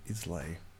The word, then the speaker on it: Islay: This is the 10-year, and it's on my favourite island, the Isle of Islay. – What? Islay. – So you don't pronounce the S.